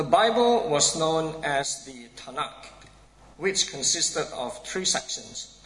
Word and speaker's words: Tanakh: which is why we get the name Tanakh, T-N-K, pronounced Tanakh.